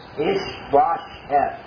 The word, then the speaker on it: Ishbosheth: In chapter 2, Ishbosheth, pretty much he pronounces like his spelled, it's Ishbosheth.